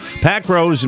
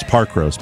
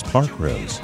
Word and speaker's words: Parkrose: Pack Rose in Portland, Oregon, too. They don't say it like that. It's Parkrose. Parkrose.